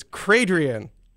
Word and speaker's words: Cradrian: It's Cradrian. Like she's Cray and then Adrian. Cradrienne.